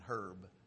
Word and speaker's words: herb: H is silent in herb.